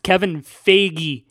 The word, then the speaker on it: Feige: It looks like it rhymes with beige, but I have heard it pronounced Kevin Feige.